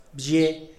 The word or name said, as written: bzhie